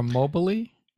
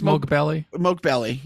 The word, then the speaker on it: Moghbeli: Moghbeli or Mobley? – No, Is no, it, it's, it's, it's Moghbeli.